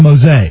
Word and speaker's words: Mosier: Well, it's spelled Mosier, but it's Bob Mose.